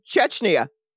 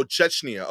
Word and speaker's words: Chechnya: Authorities in the Russian Republic of, I'm probably mispronouncing it. – No, Chechnya. – Oh, Chechnya. Okay.